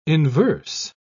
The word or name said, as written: inverse